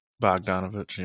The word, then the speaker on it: Bogdanovic: I almost wanted to say Brogdon Bogdanovic, or however you say his name. – Bogdanovic, yep.